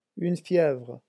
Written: une fievre

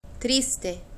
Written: triste

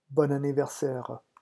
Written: bon anniversaire

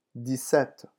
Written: dix-sept

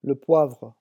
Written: le poivre

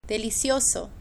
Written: delicioso

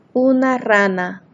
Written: una rana

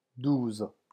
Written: douze

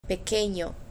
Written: pequeño

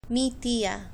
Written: mi tia

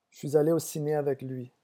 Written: je suis alle

